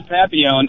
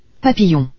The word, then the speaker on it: Papillon: I hate to agree with Tim Woodburp, but it is Papillon. I mean, to me, that is the French pronunciation. Spelling the letter, saying the letters L like that is Papillon. English.